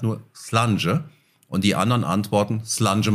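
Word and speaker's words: Slàinte: Slange spricht man das, Slange Mar oder Slange Bar, das schreibt man Slainte.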